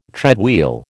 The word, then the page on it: treadwheel: American English